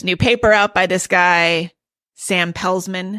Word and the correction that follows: Peltzman: Is that right? – Peltzman. You told me how to pronounce it.